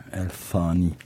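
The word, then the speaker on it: Al Thani: El Tani o. Öyle okunuyor. El Tani. – El Tani. – Tani. – Tani. Ha, tıslayarak biraz evet.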